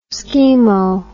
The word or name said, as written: skimo